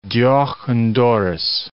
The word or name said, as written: deoch an doris